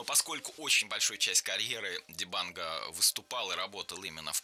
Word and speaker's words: Дибанго: Дибанго. Это, кстати, очень интересный момент. Естественно, во франкоязычных странах и французы ударение всегда перенаправляют на последний слог, но я спросил, как говорить надо, вот, а африканцы говорят Маню Дибанго.